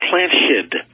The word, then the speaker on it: PlantSkydd: Well, up here we use a product called Plant Shid. – Yes. – Which is spelled PlantSkydd.